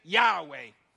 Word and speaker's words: Yahweh: And when vowels were added, that's when how eventually we got the pronunciation Yahweh, which many scholars believe is the best pronunciation.